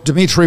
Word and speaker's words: Dimitri: Go to my profile page on Facebook, Dimitri with three I's, D-I-M-I-T-R-I.